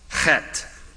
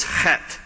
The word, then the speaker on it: Chet: It is pronounced chet. The H there is pronounced with the guttural ch. Don't turn to your neighbor and try it, or you'll spit all over his or her face. And the TH is actually a hard T, so it's chet.